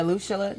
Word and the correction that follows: Alushula: Did I say it wrong? – Alushula.